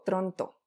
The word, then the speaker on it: Tronto: Eh, lo, que, lo que Joan Toronto, Tronto, siempre lo digo mal, lo que Joan Toronto está proponiendo en su texto, sobre todo en la primera parte, es lo siguiente.